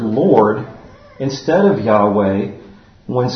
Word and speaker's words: Yahweh: So God's covenant name has four consonants in the original Hebrew, YHWH, and it's pronounced Yahweh.